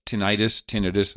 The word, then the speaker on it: tinnitus: And also there's like a tinnitus is the real pronunciation, starts to crop up a little bit.